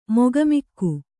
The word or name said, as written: ಮುಖಾಮುಖಿ